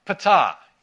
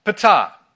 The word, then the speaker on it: Ptah: The third section is all about the god of Memphis, Ptah. Yes, that's actually how you pronounce it. I looked it up. Ptah.